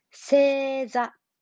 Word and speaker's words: 星座: 座るで、星が座るみたいな、こう、星座と読みます。星座。